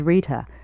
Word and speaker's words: Rita: My name is Rita, spelled R-I-T-A, and my last name, Rochelle, is spelled R-O-C-H-E-L-L-E.